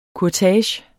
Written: kurtage

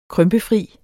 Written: krympefri

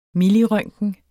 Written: millirøntgen

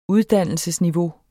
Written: uddannelsesniveau

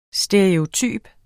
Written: stereotyp

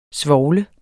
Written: svovlet

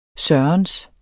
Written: sørens

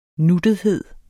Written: nuttethed

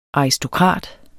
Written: ARISTOKRATERNES

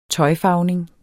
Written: tøjfarvning

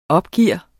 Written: opgiver

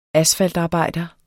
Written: ASFALTARBEJDER

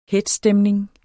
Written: hetzstemning

